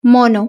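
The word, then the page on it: mono: Spanish (Spain)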